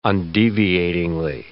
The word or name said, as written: undeviatingly